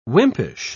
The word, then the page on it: wimpish: British English